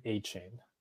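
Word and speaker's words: Achane: From what I heard in the interview clip, it's Devon Achain. That's what I've been saying.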